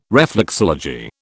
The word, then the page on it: reflexology: British English